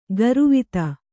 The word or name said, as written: ಕರವತಿ